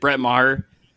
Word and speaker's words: Brett Maher: Dude, Brett Maher. Is that how you pronounce it? Brett Maher? – Yes, that's correct.